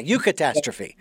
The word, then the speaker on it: eucatastrophe: The other question I have for you, as we move beyond this aspect of opportunities during the, and you pronounce that eutastrophe or eucatastrophe? Oh yeah, eucatastrophe.